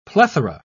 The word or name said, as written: plethora